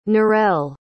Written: narelle

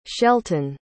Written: shelton

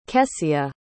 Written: kesia

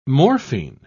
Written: morphine

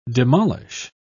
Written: demolish

